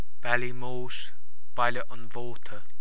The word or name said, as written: Baile an Mhóta